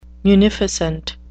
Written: munificent

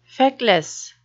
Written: feckless

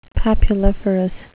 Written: papuliferous

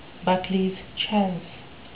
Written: buckleys chance

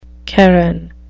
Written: charon

